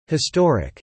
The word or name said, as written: historic